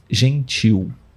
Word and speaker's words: gentil: A letra L no final da palavra nós pronunciamos como se fosse U, gentil, né?